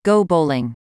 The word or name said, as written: go bowling